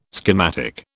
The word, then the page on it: schematic: British English